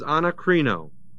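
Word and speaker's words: anakrinō: And the word judged there is anakrino. A-N-A-K-R-I-N long O.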